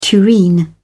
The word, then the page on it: tureen: British English